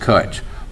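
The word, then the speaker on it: cwtch: It starts with a C, so it's C-W-T-C-H, pronounced Kutch.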